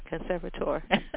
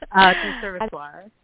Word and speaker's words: Conservatoire: Conservatory or conservatoire? – Conservatoire.